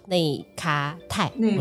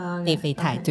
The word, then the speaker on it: 内啡肽: 他说这三种元素呢，一个是多巴胺，一个是血清素，另外一个呢是叫内咖肽。我说我发音正确，内啡肽。